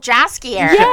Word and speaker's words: Jaskier: Jaskier. – Oh, Jaskier.